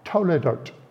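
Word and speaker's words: toledot (תולדות): There's a key word there in Hebrew that is pronounced toledot.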